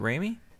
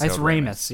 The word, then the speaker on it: Ramis: Is it Ramey? – Harold Ramis. I don't know. – I'm pretty sure it's Ramis. Yeah. – Yeah. – Yeah. I've said it with a silent S a few times.